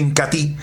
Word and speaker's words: Incatea: Se escribe Incatea, pero se pronuncia Incatí,